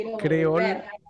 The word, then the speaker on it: Creol: Ah, ¿Cómo se pronuncia Creol. – Creol. – Creol, ahí está.